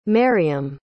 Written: maryam

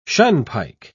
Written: shunpike

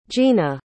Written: jeanna

